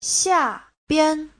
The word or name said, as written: xia4bian5